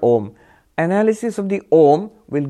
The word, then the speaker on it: Om: And because A and U together make the O, so we pronounce it Om because of that.